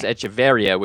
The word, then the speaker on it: Echeveria: The plant is Echeveria, which only has one R, but he is Echeveria with the two R's in the accent on the I.